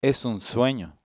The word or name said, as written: sueno